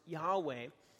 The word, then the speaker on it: Yahweh: It ends up being the what we scholars call the Tetragrammaton, which we believe is pronounced Yahweh.